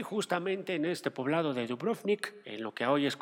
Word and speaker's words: Dubrovnik: Los historiadores eh, refieren que eh, pues lo que en su momento fue el poblado de Ragusa, que hoy llamado Dubrovnik, chécate la pronunciación, Dubrovnik,